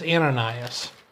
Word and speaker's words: Ananias: Certainly later on when we read about Saul going to Damascus, we find out that Ananias, I think, or is it Ananias? I don't want to say that wrong. It's Ananias.